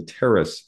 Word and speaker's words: Pteris: The little ferns, like the terrace ferns, P-T-E-R-I-S. The P is silent.